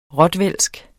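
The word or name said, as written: ROTVÆLSK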